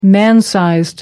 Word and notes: man-sized: American English

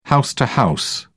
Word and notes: house-to-house: British English

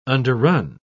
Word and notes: underrun: British English

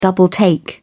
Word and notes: double take: British English

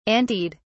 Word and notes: anteed: British English

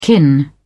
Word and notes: kin: British English

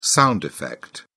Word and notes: sound effect: British English

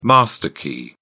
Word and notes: master key: British English

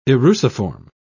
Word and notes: eruciform: British English

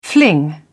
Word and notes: fling: British English

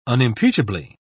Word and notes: unimpeachably: British English

